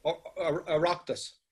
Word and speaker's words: Oireachtas: How do you say that word? Oireachtas? – Oireachtas. – Oireachtas, okay, close.